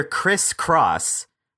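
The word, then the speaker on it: Chris Crosse: High school junior Chris Cross, spelled like Chris and then C-R-O-S-S-E, is the star of his varsity lacrosse team and has led the team to state for 2 years in a row.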